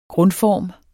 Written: grundform

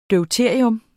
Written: DEUTERIUM

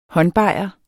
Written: håndbajer

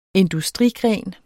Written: industrigren

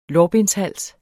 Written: lårbenshals

